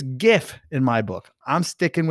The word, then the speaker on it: GIF: So before I talk about my tip from Eric Kurtz, I will start off by saying right up front, I'm a hard G guy. You'll understand this. You can give me all the excuses, but when I talk about this next one, creating your own GIFs with Google Slides, I am a hard G GIF guy.